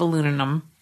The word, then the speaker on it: aluminium: But I do say aluminium instead of aluminum. – Aluminum? It is aluminium. – Is that how you say it? – Aluminum. – Aluminum. – I don't know. Our way is the right way.